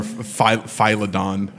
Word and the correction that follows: Philodendron: How do you say that? Philodon? – I have no idea. – Philodendron.